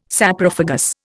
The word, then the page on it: Saprophagous: Indian